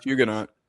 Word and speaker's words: Huguenot: I think it's – how do you pronounce it? – I think it's Huguenot.